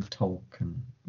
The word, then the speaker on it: Tolkien: I was thinking about calling this episode Talkin' Tolkien. Is that how you say his name? Is that even right? – Talkin' Tolkien. Yes.